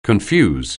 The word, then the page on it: confuse: anglais